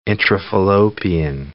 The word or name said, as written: intrafallopian